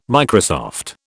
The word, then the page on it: Microsoft: British English